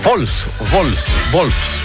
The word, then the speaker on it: Wolfsburg: No, es Wolfsburg, porque la W se pronuncia como V corta, ¿no?